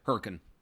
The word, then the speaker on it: Hurricane: We were actually in Hurricane, but as the Utahians pronounce it, Hurricane.